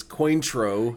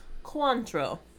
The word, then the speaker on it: Cointreau: We thought it was Cointreau. – Yeah. – It's not. – It's spelled Cointreau, but it's French, so it's pronounced Cointreau.